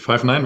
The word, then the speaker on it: Five9: Oder wie spricht man das Ganze aus? – Ja, ist richtig. Five9 wird es ausgesprochen.